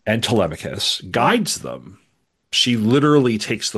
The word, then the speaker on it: Telemachus: I used to say Telemachus and somebody's like, no, it's Telemachus.